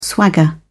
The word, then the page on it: swagger: British English